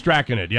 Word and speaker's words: Draconid: It's draconid. Yeah, I just looked at the pronunciation.